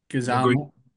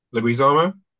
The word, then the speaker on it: Leguizamo: Guzamo. – Leguizamo.